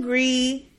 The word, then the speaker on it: Gris: You have to give a couple of other varieties a chance, namely Pinot Gris and, Pinot Grigio? – No, Gris. G-R-I-S. – Okay. – And Gewürztraminer. – Spell it. – Gewürztraminer. G-E-W-U with the little dots on it because it's German.